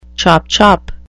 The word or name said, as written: chop-chop